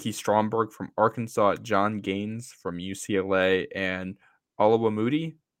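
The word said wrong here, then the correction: Oluwatimi: I think is how you say it, from Michigan. – Yes. Yep. – Oh, that's Olu Watimi. That's what it is.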